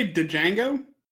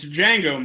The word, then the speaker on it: Django: Did you just say Duh-Jango? – Yeah. – It's Django, motherfucker. – It's Django. – Okay, whatever. – You got me. The D is silent, you piece of shit!